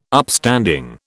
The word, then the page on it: Upstanding: British English